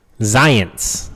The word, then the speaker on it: Zients: Zients, like science.